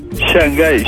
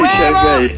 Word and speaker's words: Shanghái: Shanghái. – Ah, ya, pero ¿es bien pronunciado Shanghái? – Sí, sí, Shanghái. Sí. S-A-N-G-A-I. Shanghái.